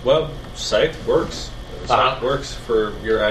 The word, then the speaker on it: Scythe: Yeah, S-I-S-C-Y-T-H-E, right? – Yeah, just like the... – Oh, Scythe. – Scythe, yeah.